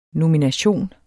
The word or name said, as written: nomination